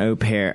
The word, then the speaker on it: au pair: Number 11 on the list seems to be way out there, but it's an au pair, I believe is how you say it. – Yeah, host an au pair.